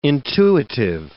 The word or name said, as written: intuitive